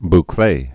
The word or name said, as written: boucle's